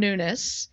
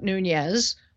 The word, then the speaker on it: Nunes: And sure enough, on Friday, what is now being called the Nunes memo, and that is how you pronounce his name. I looked it up. Devin Nunes, not Nunez.